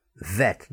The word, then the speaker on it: vet: V vet, not the wet. I didn't take my dog to the wet.